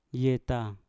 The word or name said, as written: yeta